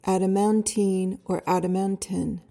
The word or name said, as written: adamantine